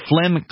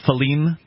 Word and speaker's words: Phelim: According to Flem Klein, the deputy director of Human Rights Watch in Asia, the operation... – The guy's name is Flem? – I'm probably mispronouncing Falim, perhaps. – Okay. – P-H-E-L-I-M. The